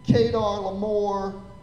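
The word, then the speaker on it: Chedorlaomer: I'm like, look, even if you go and Google this, which I did, okay, Kedar Lamor is the proper pronunciation, but it's really hard when you see CH to say Kuh, okay, like Kedar. I'm like, it doesn't look like Kedar. I wanted to say Cheddar,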